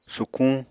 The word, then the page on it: sukuñ: Apatani